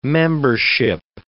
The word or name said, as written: membership